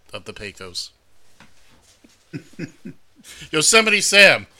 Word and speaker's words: Yosemite: when they gaze upon Yosemites, towering sequoias. – Now, as we all know, it's pronounced Yosemite.